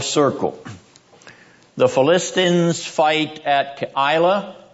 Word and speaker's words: Keilah: Nice try at pronunciation. The proper Hebrew pronunciation is Keilah. Keilah. All right, that may be a little hard for an Anglo Saxon or an Western person to get down, but you have to get a little Hebrew Semitism into your pronunciation and the rhythm of your speech.